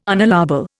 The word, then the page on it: unallowable: Indian